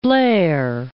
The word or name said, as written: Port Blair